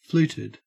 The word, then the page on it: fluted: Australian English